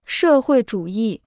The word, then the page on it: 社会主义: shè huì zhǔ yì